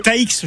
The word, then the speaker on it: Taïx: C'est Taïx, je crois, non ? – Oui, voilà, T-A-I-X.